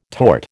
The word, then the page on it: torte: American English